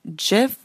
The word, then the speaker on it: GIF: GIF with a soft motherfucking G, people. Even the creator of GIF says it's a soft G. It is a soft G. It's not GIF. It is GIF.